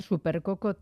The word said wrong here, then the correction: Super Soco: TC Max, que está un poquito Super Soco, ya me parecía a mí que la estaba pronunciando bien, pero se escribe Soco, Super Soco TC Max, que está un pelín por debajo en precio del anterior, ¿no?